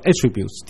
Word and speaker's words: attributes: 你当他当名词用的时候，读作 attributes, the attributes of God。